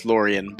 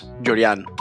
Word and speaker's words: Llorian: I'm from New York, where everyone says Llorian, but actually my parents are Cuban, so it's pronounced Llorian. But I mean, I've grown up in Croton-on-Hudson, New York, so the pronunciation became Llorian since the day I was born.